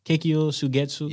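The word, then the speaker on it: Kyōka Suigetsu: That's how you say it? Kekkyo Sugetsu? – Yeah.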